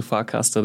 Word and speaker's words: Farcaster: Erste Frage, habe ich Farcaster überhaupt richtig ausgesprochen? Zweite Frage, wie, wo, was ist das denn überhaupt? – Ja, meines Wissens nach hast du Farcaster richtig ausgesprochen.